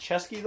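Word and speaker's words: Ceschi: It's spelled C-E-S... C-E-S-C-H-I. – Okay. – I didn't know how to pronounce it until today. I had to look it up. It's Chesky, though.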